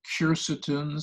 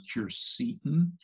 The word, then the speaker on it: quercetin: I like a plant compound called quercetin, sometimes pronounced quercetin, which is present in the white pith of citrus.